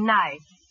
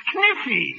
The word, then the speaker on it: knife: K-N-I-F-E. – K-N-I-F-E? That's Kniffy. – No, the word is knife. K is the silent letter. K is always silent in front of N.